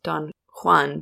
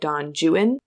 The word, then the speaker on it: Don Juan: But he also writes probably his most famous piece, which is Don Juan, which is a comedy and it's a joke on the fact that English speakers mispronounce other languages. So it's not Don Juan, but Don Juan.